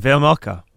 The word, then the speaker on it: Vejmelka: Did we ever figure out the proper pronunciation of his name? You're usually good at this kind of thing. – I said Vejmelka. – Oh, okay. I like Vejmelka better. – Veg, for sure.